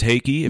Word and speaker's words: Heikki: My name is Heikki, if you did not know.